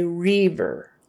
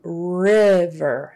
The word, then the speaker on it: river: Now, don't be tempted to say reaver. A lot of people will make that I turn into a kind of a long e sound, reaver but that will give you away. Just do river, as in bigger, big, bigger, river, eh, river.